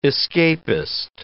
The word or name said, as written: escapist